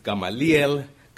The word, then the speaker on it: Gamaliel: We would pronounce the first name Gamaliel, but in Spanish it's Gamaliel Galdames.